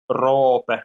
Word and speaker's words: Roope: No, I've heard it pronounced 100 different ways, but since I'm from Finland, so in Finland we pronounce it Roope, so with an O.